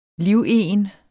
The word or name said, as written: livegen